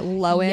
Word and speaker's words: Lohan: That sounds a lot like the Lohan family. Dina Lohan, like really, or Lohan. Yes. We all just recently learned that it's pronounced Lohan.